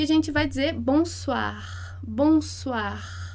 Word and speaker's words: bonsoir: O o e o i vai fazer o a. Bonsoir, bonsoir. É como se fosse suar, bonsoir.